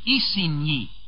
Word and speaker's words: Isigny: Cannes, by the way, is spelled C-A-E-N. It is near Isigny on your map, spelled I-S-I-G-N-Y.